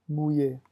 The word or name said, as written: mouiller